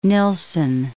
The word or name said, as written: Nilsson